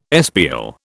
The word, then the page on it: espial: British English